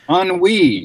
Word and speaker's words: ennui: One of them was, if I can remember the spelling, I think it was E-N-N-U-I. – Ennui. – Ennui. – Yes.